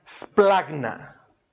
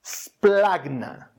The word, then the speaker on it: splagna: Están utilizando una palabra que se pronuncia splagna. Splagna.